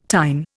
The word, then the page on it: tine: Indian